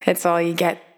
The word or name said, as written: It's All You Get